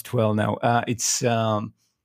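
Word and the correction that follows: Yeh Jawaani Hai Deewani: Ye Jawani Hai Diwani.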